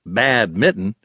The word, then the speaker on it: badminton: Me and all my cousins would play in our grandma's yard, while the adults tried their hand at badminton, pronounced bad-mitten in Ohio, or horseshoes, or jarts.